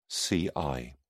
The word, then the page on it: ci: British English